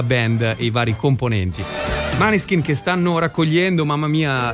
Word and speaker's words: Måneskin: Allora, a proposito di rock, è scoppiata la Maneskin, anzi Måneskin, come oramai abbiamo capito bisogna pronunciarli, la Måneskin mania.